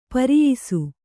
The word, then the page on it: ಪ್ರಾಯಶಃ: Kannada